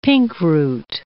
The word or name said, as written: pinkroot